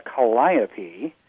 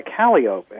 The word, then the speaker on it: calliope: And someone down there was calling what I would call a calliope. They would pronounce it a calliope. And when I said, ooh, you're wrong, they said, no, it's a calliope when it's on land, and it's a calliope if it's on a steamship, a riverboat.